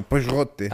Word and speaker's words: Pixote: Pero si han visto Ciudad de Dios y no han visto Pijote, creo que se escribe, se escribe P-I-X-O-T-E. Sí, es como Pijote.